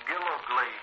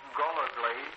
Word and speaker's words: Gillogly: The first name, Fred Dale Gilligley, Jr. That name can also be Gulligley. It's spelled that way in the nomenclature. – First Lieutenant Fred Dale Gilligley, Jr., or Gulligley, G-I-L-L-O-G-L-Y, or G-O-L-L-O-G-L-Y.